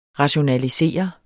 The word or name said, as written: rationalisere